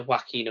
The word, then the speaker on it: Joaquin: But we've got Rey Mysterio, Santos Escobar, and either Cruz del Toro or, how am I pronouncing this one, Joaquin Wild. Or like Joaquin Phoenix, there we go.